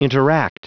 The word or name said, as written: interact